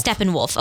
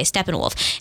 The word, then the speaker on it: Steppenwolf: Steppenwolf. Okay, Steppenwolf.